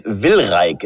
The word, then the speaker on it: Wilrijk: Und also hier steht jetzt Wilrijk. Habe ich das richtig ausgesprochen? – Genau.